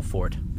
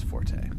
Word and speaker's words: forte: Or were you actually talking about a literal fort? – No, I don't really want to get into it, but it's actually properly pronounced fort, but everyone just says forte.